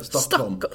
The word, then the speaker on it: Stockholm: Yeah, Stockholm. – Stockholm. – Have you never been to Sweden? – I've been to well, obviously Malmö and Lund. I don't think I've ever been to Stockholm.